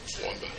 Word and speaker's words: Waumba: W-A-U-M-B-A is the way it's spelled over there. Wumba.